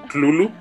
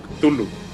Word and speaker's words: Cthulhu: De hecho, creo que existe una carta donde él aclara la forma correcta de pronunciarlo. Que según él se pronuncia como Clulu, pero. Pues no, o sea, la verdad es que todos lo conocemos más como Cthulhu.